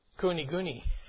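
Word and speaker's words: kuniguni (国々): But it says countries there, so it's plural. So that is kuniguni.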